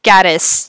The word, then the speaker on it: Gatiss: And, fuck you, Steve Moffat and Mark Gates is for that. – Gatiss, yeah. Maybe I'll make it a thing and always try to mispronounce Mark Gatiss's name.